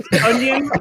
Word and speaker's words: Tonyan: Tonyan like a Funyan. That is how it is pronounced.